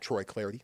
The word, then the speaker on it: Clardy: You can follow me on Twitter, as mentioned, at Troy Clardy. Last name is spelled C-L-A-R-D-Y.